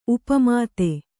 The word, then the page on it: ಉಪಮಿತ: kannada